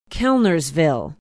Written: kellnersville

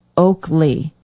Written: oakley

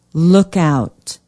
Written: lookout

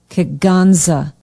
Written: kegonsa

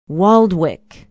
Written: waldwick

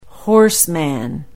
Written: horseman

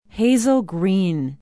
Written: hazel green

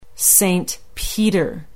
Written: Saint Peter

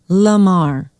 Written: Lamar

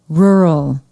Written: rural

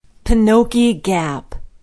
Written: penokee gap

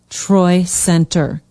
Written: troy center

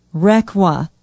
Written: requa

